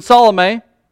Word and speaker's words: Salome: When the Sabbath was passed, Mary Magdalene and Mary, the mother of James, and Salome... And yes, I did. I've Googled her name several times to figure out how to say it, so that is correct, in case you guys wondered.